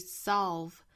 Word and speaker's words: solve: 最后的一个词是 solve 美式发法也是同样这里面的元音读成 a solve 跟刚才那个 problem 也是一样的 a problem solve 那同样注意里面的舌侧音舌尖去抵上齿龈 solve 好美音我们再来读一下 When we are our best selves, there isn't a problem that we cannot solve.